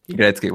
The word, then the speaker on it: Gretzky: Eh, mirando si, si Wayne Gretzky, o ¿cómo se dice? Gretzky. – Wayne Gretzky, sí, sí.